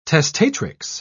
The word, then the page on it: testatrix: British English